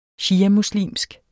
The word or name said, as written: shiamuslimsk